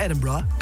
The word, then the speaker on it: Edinburgh: Ik ga morgen met mijn vriendinnetje voor 4 dagen naar Edinburgh. Zo moet je het volgens mij uitspreken. – Ja, Edinburgh.